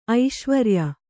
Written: ಐಶ್ವರಿಯ

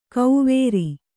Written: ಕೌವೇರಿ

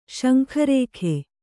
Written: ಸೊಂಕುರೋಗ

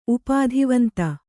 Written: ಉಪಾಧಿವಂತ

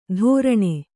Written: ಧೋರಣೆ

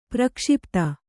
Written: ಪ್ರಕ್ಷಿಪ್ತ

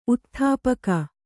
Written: ಊದುತುಪಾಕಿ

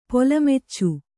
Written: ಪೊಲಮಚ್ಚು

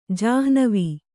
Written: ಜಾಹ್ನವಿ

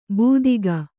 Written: ಬೂದಿಗ

